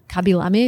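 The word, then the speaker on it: Khaby Lame: You don't have to say a word in your language, which is Kabilame is the right way to pronounce the number one TikToker.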